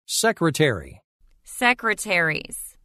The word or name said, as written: Secretary – Secretaries